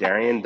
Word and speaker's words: Darion: it's Darion D'Anjou, as as Natasha so well pronounced it, Darion D'Anjou.